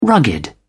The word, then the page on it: rugged: British English